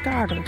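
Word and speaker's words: gaardu: ugly wiggity word is garter.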